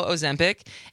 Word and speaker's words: Ozempic: Ozempic, and Wegovy.